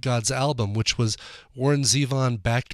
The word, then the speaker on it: Zevon: That's Billy Bob Thornton and a cover of The Wind that he recorded for the Enjoy Every Sandwich, the songs of Warren Zevon. Well, I almost did it, Warren Zevon. See? Gotta catch me on that. Make sure I don't go off the wagon in my pronunciation of Zevon.